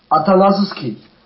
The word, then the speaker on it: Atanasoski: Did I say that right? – Atanasoski. – Yeah. Almost right.